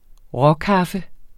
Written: råkaffe

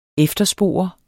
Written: efterspore

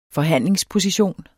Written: forhandlingsposition

